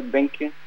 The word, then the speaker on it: Behncke: Sí, en todas las plataformas por mi nombre, Ragnar Behncke.